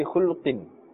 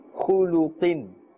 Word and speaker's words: Khuluqin: Be careful not to say khulqin. This lam which has a dhamma must be made clear. Khuluqin.